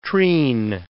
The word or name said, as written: treen